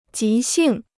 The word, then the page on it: 急性: jí xìng